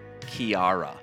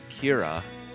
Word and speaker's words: Ciara: And some people, I'm sure plenty of people, said Ciara. – Right. – Or Sierra. – Right. – As some people would say. However, the correct pronunciation, as lots of people from Ireland pointed out, it is Kira.